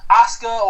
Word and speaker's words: Asuka: It's pronounced Asuka. Asuka. – Or is it Asuka? – Yeah, because okay.